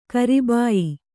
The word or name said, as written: ಕರಿಬಾಯಿ